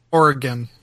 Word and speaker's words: Oregon: Is it Oregon or Oregon? – Oregon. – Oregon. – It's not Gary McKinnon. – Mm-hmm. Hey, Nicole! Hey, Nicole! Yeah. Apparently I'm saying Oregon right.